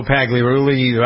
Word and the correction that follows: Pagliarulo: I never pronounce it. Pagliarulo, right? – Yeah, it's Joe Pagliarulo.